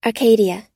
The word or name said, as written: arcadia